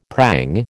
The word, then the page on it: prang: American English